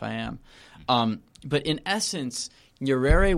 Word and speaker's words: Nyerere: Nyerere.